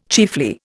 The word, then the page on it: chiefly: Indian